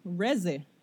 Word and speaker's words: Reze: Y Denji en este otro arc conoce a esta nena que se llama Reese Reze. Se escribe R-E-Z-E.